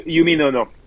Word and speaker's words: Uminono: Now, finally, your sushi business in Prahran, in St. Edmunds Road, it's called Uminono. Is that correct how you say it? – Yeah, Uminono. Yes, correct.